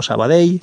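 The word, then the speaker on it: Sabadell: Y, bueno, pues eso, que en valenciano eh, eso se pronuncia como una L o como una Y, si queréis, eh, y se diría pues eso, carbonei, o sabadei, o martorei o no sé, mm, cualquier otra cosa que se os pueda ocurrir.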